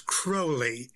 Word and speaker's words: Crowley: Well, the way Aleister Crowley pronounced it was Crowley, and he said it rhymes with holy.